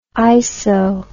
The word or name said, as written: iso